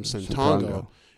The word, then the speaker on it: Ssetongo: The only reason why it was like Benny Santongo straight from the Congo is because my last name, Santongo, is rhymes with Congo.